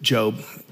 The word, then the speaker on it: Job: If you don't know the story of Job, you can look it up in the book of Job. It's spelled like Job, but it's pronounced Job, apparently.